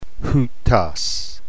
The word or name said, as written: houtos